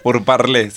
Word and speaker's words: Purparlé: Se escribe P-U-R-P-A-R-L-E. – Como púrpura, Purparlé.